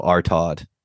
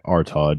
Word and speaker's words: Artaud: No, Artod. The drunk dude falls. – Oh, the drunk dude. Yeah, Artod. Yeah. A-R-T-A-U-D.